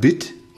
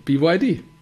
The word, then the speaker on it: BYD: Sagt man dann Bit oder Büt? – Nee, BYD.